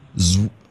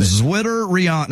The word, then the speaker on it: Zwitterion: The word is Zwitterion. Zwitterion. Zwitterion. – So, of course, I'll be able to spell it when you can't even pronounce it. – Zwitterion. Now, I'm pronouncing it Zwitterion. – Z-W-I-T-T-E-R, Zwitter, Zeon? – You started off right.